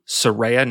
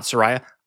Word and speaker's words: Saraya: When Bob and I recorded about the upcoming match, I kept pronouncing Saraya as Saraya. Bob tried to correct me, warned me that this was a big thing in AEW, Saraya, not Saraya.